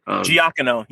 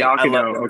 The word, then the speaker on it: Giacchino: I might butcher his name, but like Michael Giacchino. I forget his name. – Giacchino. Yeah, he's tight. – Giacchino, okay.